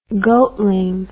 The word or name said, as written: goatling